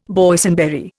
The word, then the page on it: Boysenberry: Indian